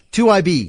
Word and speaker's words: 2IB: Who is the founder and chief executive at how do you pronounce it? – 2IB. – 2IB. – Yeah. – 2IB. It looks like 2IBI.